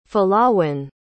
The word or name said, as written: fallamhain